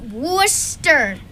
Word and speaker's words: Worcester: My little piece of paper says Worcester. – That's how it looks. It's actually pronounced Worcester.